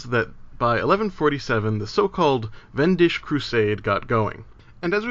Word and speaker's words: Wendish: The Wendish Crusade is spelled with that most pesky of items, the German W, which is pronounced with a V sound.